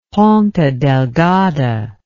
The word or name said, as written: ponta delgada